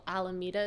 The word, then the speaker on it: Alameda: I think it's called Alameda is how you pronounce yes.